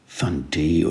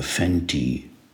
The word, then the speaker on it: Fante: Heute möchte ich ein äh, dreibändiges Werk, eine Trilogie empfehlen von einem Außenseiter unter den amerikanischen Schriftstellern, der den Namen John Fante oder Fenty trägt, je nachdem, wie man ihn aussprechen möchte.